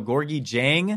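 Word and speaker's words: Gorgui Dieng: So Gorgie Jang, I believe it's pronounced.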